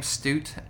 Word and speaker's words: Stute: I, th- think that's how you pronounce his name, Stute, S-T-U-T-E.